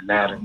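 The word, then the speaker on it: Naoero: The way you say Nauru in Nauruan is Naro.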